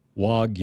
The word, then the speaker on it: Wagyu: So it's pronounced Wagyu. – Wagyu. – Wagyu is how you pronounce it in Japan.